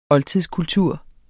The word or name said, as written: oldtidskultur